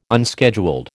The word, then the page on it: unscheduled: American English